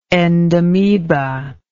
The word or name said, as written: endameba